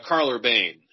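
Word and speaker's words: Karl Urban: In the show, it's Carl Urbane, and he is just loving it. And he's so good in that role. – Is that the emphasis you put on the syllables? – Exactly.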